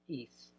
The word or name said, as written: eis